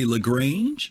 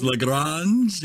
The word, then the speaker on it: Lagrange: If you read phonetically, you'd probably say Lagrange, but if you know, it's Lagrange, if you want to say the right thing,